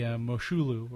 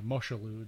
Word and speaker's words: Moshulu: And there's some really fun stuff, like on the Moshulu, or the Moshulu, depending on how you want to pronounce it.